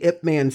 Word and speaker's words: Ip Man: Yes, IP Man. – Yeah, I thought it was IP, like Internet Protocol, maybe? – Not Internet Protocol, but IP, yes.